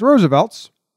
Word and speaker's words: RSVLTS: If you've seen that brand and you're not sure how to pronounce it, it's Roosevelt's. We asked them. They told us.